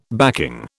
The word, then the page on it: backing: British English